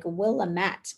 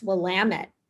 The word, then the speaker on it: Willamette: And I always thought it was like Willamette, but it's Willamette. And they always say it rhymes with damn it. That's how you remember it. Willamette, damn it.